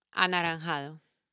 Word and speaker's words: anaranjado: So naranjas and the color is anaranjado. – Okay. – Okay, so what is the color orange? – Anaranjado. – Anaranjado. Anaranjado. – It's just one word, anaranjado.